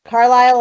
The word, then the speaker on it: Carlisle: Carlisle, like the town in Pennsylvania. Yeah. The man's name, Carl, and Isle, like an island.